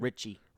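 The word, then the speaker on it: Ricci: During filming, Bill Pullman and Christina Ritchie, is that how you say your last name? – Ritchie.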